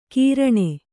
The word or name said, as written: ಕೀರಣೆ